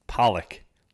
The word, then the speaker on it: Pollock: How do you say his name? – Pollock? – Pollock. Like the fish.